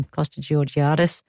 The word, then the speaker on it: Costa Georgiadis: Okay, also on this weekend, tomorrow at Northeast Street Farm in Windsor, Costa the Garden Gnome, also known as Costa Georgia, I can never say his name, Costa Georgiadis, from Gardening Australia.